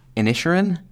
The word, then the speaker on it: Inisherin: The Best Actor nominees are Austin Butler in Elvis, Colin Farrell, The Banshees of Inisherin. Am I pronouncing that right? I'm trying not to say Ed Sheeran.